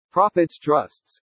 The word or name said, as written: PROFITS TRUSTS